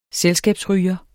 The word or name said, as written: selskabsryger